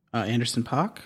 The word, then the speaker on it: Anderson .Paak: How do you pronounce this fellow's name? – Anderson .Paak? – Yeah. – Yeah, it's like that.